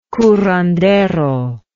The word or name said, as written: curandero